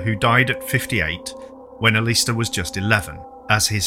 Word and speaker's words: Aleister: Edward Alexander Crowley, known as Aleister, or Alistair, depending on how you pronounce it. I prefer Aleister.